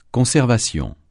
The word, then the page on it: conservation: francais